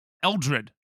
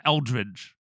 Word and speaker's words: Eldred: You know, my last name is spelled how I say it, Eldred. But people always add a G. So I'm Eldridge.